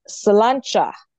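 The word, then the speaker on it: sláinte: So you say salancha.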